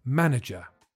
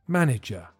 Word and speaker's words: manager: The answer is manager. This one's much easier to pronounce, but listen to the middle syllable because it sounds like a weak I. Manager.